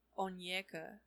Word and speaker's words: Onyeka: Onika. – Onyeka. – It's Onyeka? – Yeah. – Okay. – I think. – I don't know how to pronounce it. – The Y comes before the E.